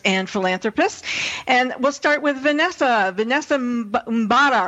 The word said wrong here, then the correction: Mbamara: Oh, you messed it up. – The same way that I did. – I messed up her name too. It's Mbara. – Yeah, how do you say it, Vanessa? – Mbamara. It's Vanessa Mbamara.